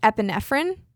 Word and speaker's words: epinephrine: And then they noticed that they were missing epinephrine.